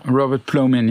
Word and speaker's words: Robert Plomin: Du hast dich mit einem Wissenschaftler auseinandergesetzt, Robert Plomin heißt der, habe ich das richtig ausgesprochen? – Ja, Robert Plomin, ja.